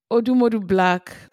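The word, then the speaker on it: Odumodublvck: Odumodu Black. – Okay. Black. – But the Black is spelled B-L-V-C-K. – And it's like blocks.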